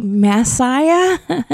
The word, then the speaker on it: Massiah: See, it's Massiah. I can manipulate mass and density. It's a scientific play on words. And it rhymes with Zaya. See, Massiah.